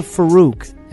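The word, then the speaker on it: Farruque: I'm always getting this name wrong. San Cheetah Farouk, and that's F-A-R-R-U-Q-U-E, from his latest project, Autonomy, the Fourth Quarter 2.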